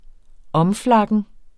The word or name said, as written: omflakken